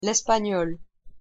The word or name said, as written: l'espagnol